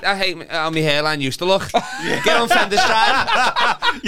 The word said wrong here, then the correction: finasteride: You're saying it wrong as well. It's Finasteride. – Is it not Fendestride? – No.